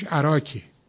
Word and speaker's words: عراق: سبک عراقی، عراق، امروز ما به کشور عراق میگیم، یا بعضی میگیم عراق عرب و عراق عجم. عراق عجم که همون فارسیش عراکه، شهر عراق تلفظ فارسی عراقه، و امروز یه شهر استان مرکزی ما فقط عراقه.